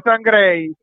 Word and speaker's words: Sam Gray: E vedremo se Sam Gray continuerà a fare magie come finora. – Eh sì, hai detto bene San Gray.